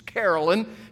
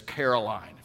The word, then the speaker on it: Carolyn: Her name is Carolyn, and he kept referring to her as Caroline.